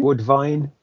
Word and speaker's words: Woodvine: John Woodgene, Wooddine? – Woodvine. – Woodvine. It's a V.